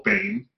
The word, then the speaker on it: Bain: Mitt Romney had a company called Bain, B-A-I-N, that was pronounced but not spelled the same way that the villain in the new Batman movie is pronounced.